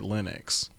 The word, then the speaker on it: Linux: Either I've only ever heard it Linux.